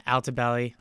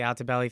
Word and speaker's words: Altobelli: I'm just going to look it up real quick because, yeah, Altobelli. Okay, so I got it right. Just wanted to make sure I was pronouncing it right. The Altobelli family, along with several others.